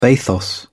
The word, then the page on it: bathos: British English